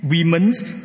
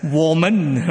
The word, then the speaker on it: women: Then I realized that W-O-M-E-N, women, in Han Yu Pinyin is not pronounced as women, it's pronounced as woman, right?